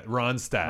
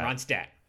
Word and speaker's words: Ronstadt: You're adding an N, I feel like. – Yeah. – There is an N. – Ronstadt. I can't. Stat. I put an N in. – That's so weird. Just say it like Stat Holiday.